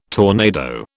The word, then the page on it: tornado: British English